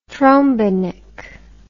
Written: trombenik

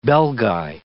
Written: Belgae

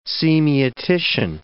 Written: semiotician

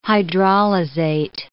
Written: hydrolyzate

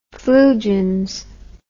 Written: flugens

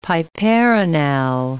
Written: piperonal

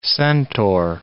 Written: centaur

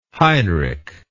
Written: heinrich

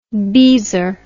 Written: beezer

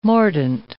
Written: mordent

